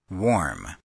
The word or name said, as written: warm